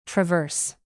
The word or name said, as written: traverse